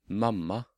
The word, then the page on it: mamma: Norwegian